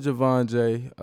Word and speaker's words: JVNJAY: So, my name is JVNJAY. A lot of y'all probably know me as JT, but I decided to change my name to JVNJAY. Javon is my middle name. It's just spelt with no vowels.